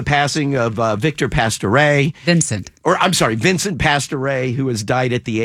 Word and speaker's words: Pastore: How do you say his last name? – Pastore? – No, but some people are saying pistoray.